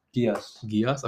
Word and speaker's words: Geass: Geass.